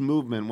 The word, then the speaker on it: MVMT: It's MVMT, pronounced movement. When you go to look it up, it's MVMT.